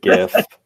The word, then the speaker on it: GIF: Hard G all the way, GIF. – GIF is what I say too. – Yeah. – Go ahead. – I think the argument that I hear most is that the creator of the GIF pronounces it with a soft G, but you know what?